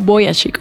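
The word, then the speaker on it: Vujicic: En su libro él dice, él dice cómo se pronuncia, dice mi, mi apellido se pronuncia y él lo dice y lo escribe y es como Boyashik o algo así.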